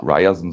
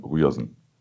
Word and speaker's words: Ryerson: Äh, ein Hinweis, der gute Mann heißt nicht ah. Ryerson, sondern Ryerson.